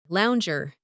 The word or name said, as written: lounger